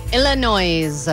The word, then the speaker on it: Illinois: Illinois. – I don't know if I can give you the point for pronouncing the S at Illinois. – The end of that.